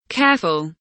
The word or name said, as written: careful